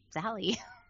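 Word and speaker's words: Salli: Um, even my first name, people are like, is it Sally? Is it Sally? I'm like, it's just Sally.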